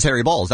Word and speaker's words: Baals: It is spelled B-A-A-L-S, but he himself called it was Harry Balls.